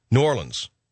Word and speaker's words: New Orleans: New Orleans.